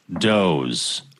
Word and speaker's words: Dawes: Looking forward to the Da Wez podcast. That's a, yeah. – I think that's pronounced Doze. – Oh, is it? – I think so. – Okay. I think it's pronounced Doze.